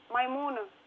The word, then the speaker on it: Maimouna: F-A-R-A-C-N-I. – Okay. And finally, what is your name? – Maimuna. – How do you spell that? – M-A-I-M-O-U-N-A.